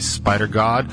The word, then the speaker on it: Spidergawd: And the band is spelled S-P-I-D-E-R-G-A-W-D, which in my pronunciation would be Spider God.